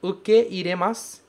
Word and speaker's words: ukeiremasu: Ele tem essa pronúncia que pode parecer pela escrita que é de uma vogal longa, mas na verdade não é. O ke e o i são pronunciados separadamente. Ukeiremasu.